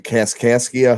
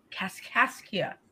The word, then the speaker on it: Kaskaskia: The Kaskaskia Hotel. – Kaskaskia.